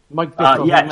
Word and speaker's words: Mike Bithell: somebody in the UK, I think Mike Bithell. I don't know how to pronounce his name. – Mike Bithell.